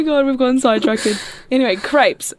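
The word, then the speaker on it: crêpes: Crepes. – Crepes. – Yeah. – Crepes. – No, crepes, not creeps.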